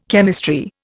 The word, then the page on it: chemistry: Indian